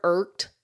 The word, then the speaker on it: irked: Even the word like stress, S-T-R, that's still three consonants right in a row. – Right. – I think of the word irked, because even though there's that E-D at the end, if we were to write it phonetically, you don't put that E there. It's just a T, irked. We're saying T there. So you have R-K-T.